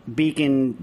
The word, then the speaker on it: BKON: And we just spelled it a little bit differently, but it's pronounced as in beacon, like a beacon of light, signal, a pathfinding illuminator.